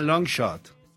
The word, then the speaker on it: LNSHT: ¿Cómo se pronuncia? Elgin, ¿qué? Ah, Long Shot.